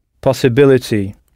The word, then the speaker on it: possibility: Possibility, en frappant la syllabe B, possibility.